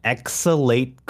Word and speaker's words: Exhalate: It's Under Night In Birth Exhalate Clear. I looked it up. – It's not even EXE. – No, it's Exhalate. I looked it up.